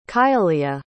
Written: kayaliya

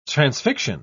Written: transfixion

